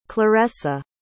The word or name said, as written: claressa